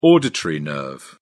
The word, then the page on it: auditory nerve: British English